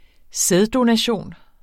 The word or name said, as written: sæddonation